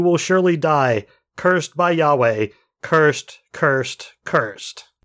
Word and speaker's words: Yahweh: because there's no vowels, but in theory you would pronounce it Yahweh in Hebrew.